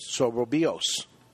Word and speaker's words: Soberbios: They have the Soboroso. Am I saying that right? Soberbios? Soberbios. That's what I said.